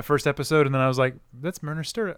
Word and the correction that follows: Marina Sirtis: How do you pronounce her name? – Marina Sirtis. Yeah. – Marina Sirtis. Thank you.